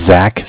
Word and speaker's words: Zach: My name is Zach, spelled Z-A-C-H. Was just following up with him. I'm from Cardiff. That is spelled C-A-R as in car, D-I-F-F as in different, like Cardiff, Wales in England.